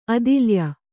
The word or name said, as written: ಅದಿಲ್ಯ